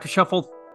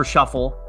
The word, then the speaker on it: Schöffl: Dr. Shuffle mentioned his Instagram. That handle for anyone who wants to follow him is at Volker Shuffle, but keep in mind it's German pronunciation. So his name is spelled VolkerShoeffl.